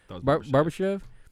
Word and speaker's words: Barbashev: I get you just added Ivan Barbashev, I believe is how you say his name. – Barbashev.